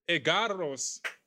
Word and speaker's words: Egarros: Christopher Michael Egarros. Did I say it correctly? – Oh my God, the first guy to get that name right. Roll the R.